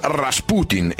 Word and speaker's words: Rasputin: Incominciamo subito col dire che si pronuncia Rasputin e non Rasputin o Rasputin come alcuni dicono, perché il suo cognome deriva dall'aggettivo Rasputni, che in russo significa depravato, in nome nomen, come si suol dire, nel nome il presagio.